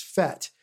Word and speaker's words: fête: Now, by the way, the British pronounce this word fate, it sounds like F-A-T-E, but it's spelled F-E-T-E. And in French, it's fête, which means party or festival or something like that. So it's a French word that they pronounce wrong.